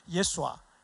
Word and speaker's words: 예수아: 이게 야훼라는 발음이 원래는 더 정확한 발음이고 예수님이라는 발음은 원래는 예수아. 이게 더 정확한 발음이에요.